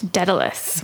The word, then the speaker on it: Daedalus: Daedalus? – Daedalus.